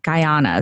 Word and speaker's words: Guyana: Tell me about you grew up in Guyana.